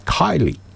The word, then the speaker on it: Keighley: They're from Kylie. Kylie, I suppose. Would you pronounce it Kylie? I suppose. K-E-I-G-H-L-E-Y? Yes.